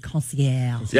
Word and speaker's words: concierge: And if you don't know how to spell it, because it's a fancy French word, C-O-N-C-I-E-R-G-E, Concierge. – Yeah, like if you want to sound it out, it's Concierge. But it's Concierge if you want to be not fancy, it's Move Concierge.